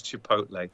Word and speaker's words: Chipotle: I hear it called Chipotle as well. There's no accent on it, but that's the only way I've ever heard it pronounced is Chipotle.